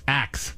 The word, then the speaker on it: ask: Ask is changing. – What do you mean? – Ask. If you ask a question. – Yeah. Ax. – Uh-huh. – Yeah. – This pronunciation has been around so long, over 1,000 years, that linguists think that we should cherish it as part of our heritage.